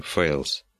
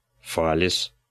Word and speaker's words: Фалес: А что интересного в том, что он взял себе псевдоним Фейлс?» «Ты неправильно произносишь». Впрочем, как и мистер Баллу. – Разве не Фейлс? – Нет, конечно. Напиши эту фамилию латинскими буквами, но прочти не по-английски, а произнося каждую букву. Что получится? – Фалес.